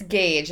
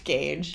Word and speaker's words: Gage: It's Gage. It's just spelled very weird, but it is pronounced Gage.